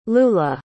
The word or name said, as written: Lulah